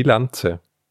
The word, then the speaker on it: Lanze: L-A-N-Z-E, wie Lanze. – Ja, dann Lanze.